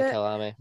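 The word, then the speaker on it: Kyalami: Kalami, I think it's how you pronounce it. Kalami, yeah, Kalami.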